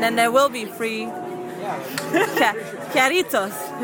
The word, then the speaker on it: Jarritos: J-A-R-R-I-T-O-S. – Jarritos? – See, that's what I said.